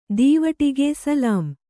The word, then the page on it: ದೀವಟ್ಟಿಗ: dīvaṭṭiga